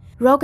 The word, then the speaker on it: Rogue: Camilla und Rogu? Es wird englisch ausgesprochen. Rogue, korrigierte ihn seine Kollegin.